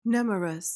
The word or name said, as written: nemorous